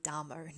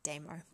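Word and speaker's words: Damo (다모): And Danji is a demo, or probably if you had a, you know, actually said it properly in Korean and didn't have my weird accent, you would say probably Damo, not demo, but there you go.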